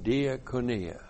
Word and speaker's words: diakonia: And then we have diakonia. D-I-A-K-O-N-I-A.